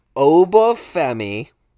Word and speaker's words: Uba Femi: So Uba Femi, am I saying it right? – Mm-hmm.